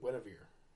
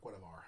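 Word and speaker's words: Guenhwyvar: It's Driss Newarden and Guinevere, or Guinevar, however you want to pronounce it, from R.A.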